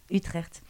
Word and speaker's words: Utrecht: Utrecht, aux, Pays-Bas, depuis un an et demi.